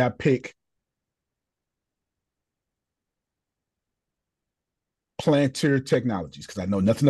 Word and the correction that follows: Palantir: I didn't even say the name right. Palantir. – Palantir.